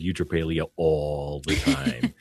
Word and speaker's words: eutrapelia: It's like a virtue called like eutrapelia.